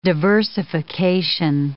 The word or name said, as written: diversification